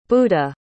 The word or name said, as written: buddha